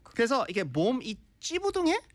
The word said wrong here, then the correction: 찌뿌둥: 찌뿌뚱. 찌뿌뚱. – 찌뿌뚱해요. – 찌뿌뚱해. 네. – 찌뿌뚱해. – 찌뿌뚱. 이게 쌍이 뭐라지? – 맞아요. – 쌍지지 and 쌍이 비읍부. 네. – 네네네. – 그래서 찌뿌뚱.